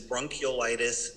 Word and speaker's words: bronchiolitis: So both bronchiolitis, and by the way, it is, and you said it correctly, it is bronchiolitis, not bronchitis.